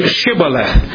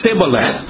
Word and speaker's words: Shibboleth: If he said no, then they would say to him, "Then say Shibboleth," and he would say Sibboleth, so he could not pronounce it right.